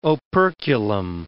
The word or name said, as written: operculum